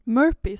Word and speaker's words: Merope: Merope? Fan uttalar man hennes namn. – Merope, säger jag.